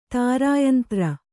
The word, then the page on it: ತಾರುಯಂತ್ರ: tāru yantra